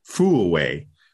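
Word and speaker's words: Furue: Her last name is pronounced Fuaway, and her first name is AYAKA, and I'll screw it up, so I'm not even going to pronounce it.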